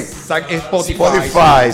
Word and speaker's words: Spotify: Spotify, Spotify, Spotify.